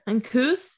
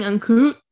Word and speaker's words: uncouth: Uncouth? – Uncouth. – Yeah. I was saying uncoot.